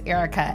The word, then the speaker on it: Erika: My name is Erica Edwards, and you can follow me at all social media at Erica D.